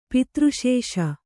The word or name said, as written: ಪಿತೃಶೇಷ